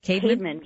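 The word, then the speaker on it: Caedmon: And you described the experience of a poet named Cademan. Am I saying his name right, Angie? – Cademan, yes.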